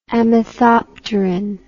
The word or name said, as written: amethopterin